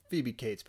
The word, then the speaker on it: Phoebe Cates: I like Phobe Cats. I wonder where she went. – No. – Phoebe Cates, by the way. I'm just one time in the podcast, I'm going to say it correctly.